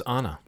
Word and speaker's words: Anna: I've discovered it's Anna. – Anna Faris, yes. Anna Faris, you're right. – I've been saying it wrong all this time.